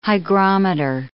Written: hygrometer

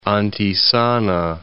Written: Antisana

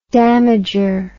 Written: damager

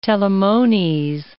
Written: telamones

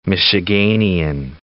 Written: Michiganian

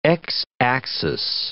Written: x-axis

